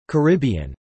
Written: Caribbean

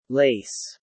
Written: Lace

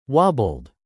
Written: wobbled

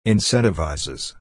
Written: incentivizes

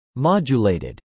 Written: modulated

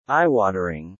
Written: eyewatering

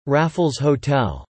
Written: Raffles Hotel

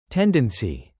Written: tendency

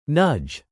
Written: nudge

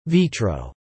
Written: vitro

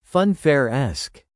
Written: funfair-esque

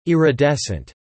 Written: iridescent

